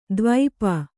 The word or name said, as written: ದ್ವೀಪಿ